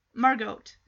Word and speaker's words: Margot: Margot. But it's just, I've always pronounced it as the T is silent. Because I think of Anne Frank, her sister's name is Margot. And it's spelled M-A-R-G-O-T.